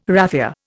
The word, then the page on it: raffia: Indian